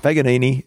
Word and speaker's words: Paganini: It's P-A-G-A-N-I-N-I.